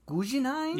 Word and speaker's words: Guggenheim: Guggenheim? – Yeah.